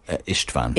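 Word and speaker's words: István: First off, am I pronouncing his name correctly? How would you say? – Istvan. – Istvan, okay.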